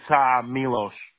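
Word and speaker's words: Miloš: It's Milosh. – Milosh, okay, but it's without the H, it's just with an S, correct? – Yes.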